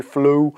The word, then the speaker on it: The Floow: And in the telematic space, um, Otonomo acquired uh, the UK business The Floow, I think it's how you pronounce it, F L O O W, Flow, uh, for 24 million.